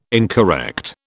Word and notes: incorrect: British English

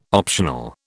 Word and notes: optional: British English

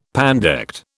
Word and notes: pandect: British English

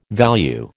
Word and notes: value: American English

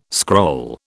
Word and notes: scroll: British English

